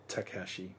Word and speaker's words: Takashi: Takahashi Miike. – Yep, it's Takashi Miike. – Takashi? – Yep. – Oh, there's no H in that. Takashi Miike, that has just seen very limited release in Australia, starring Ebizo Ichikawa, Aita, Hikari Mitsushima, and Koji Yakusho.